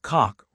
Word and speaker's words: Koch: Mark Koch rhymes with Coke, is a former producer who recently detailed a transition from a career in entertainment to a life centered on faith-based practice.